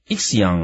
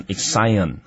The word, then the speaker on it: Ixion: 那 Ixion 啊，也有人念成 Ixion 啊，都可以。